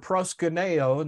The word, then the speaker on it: proskuneo: Now, this word, prayer of worship, the word worship in the New Testament comes from the Greek word proskuneo, and let me spell it for you. It's P-R-O-S-K-U-N-E-O.